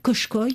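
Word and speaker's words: Kuşköy: Alors cette langue du village turc de Koskoy, hein, au bord Koshkoy, de la... – Ouais. – Koshkoy, on dit.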